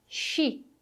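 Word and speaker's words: sci: Chi. Chi. Atenção, não vamos usar um som de S. Não é si, mas chi. Esse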